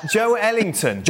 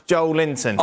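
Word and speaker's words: Joelinton: Yeah, I'm not a pedant when it comes to pronunciations, but literally at the top of my, my list here, it's our Brazilian uh, researcher, and he wrote Joe Linton is pronounced Joe Ellington. It's not pronounced Joe Linton.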